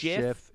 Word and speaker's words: GIF: Although I do believe it's pronounced GIF, legit. – We did. We saw that. – It's GIF. – It's confirmed GIF by the creator of GIF.